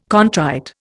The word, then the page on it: contrite: Indian